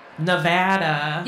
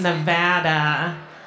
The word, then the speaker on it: Nevada: Nevada. – Yeah. – You pronounce it Nevada. I have a hard time saying that.